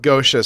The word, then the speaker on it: Goetia: I've heard it pronounced Goetia, Geisha, Goetia, like... I go with Goetia or Geisha. – Yeah. I'm going to go with Goetia so that people don't think we're talking about Japanese hookers.